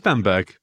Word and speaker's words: Bamburgh: It is Bamburgh. B-A-M-B-U-R-G-H, as I remember it. – Yeah, Bamburgh. It's not like Edinburgh.